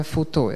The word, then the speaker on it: FUTO: Właściwie FUTO się wymawia FUTU jako FU2, tak? Pisze się FUTO.